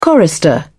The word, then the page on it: chorister: British English